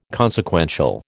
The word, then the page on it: consequential: American English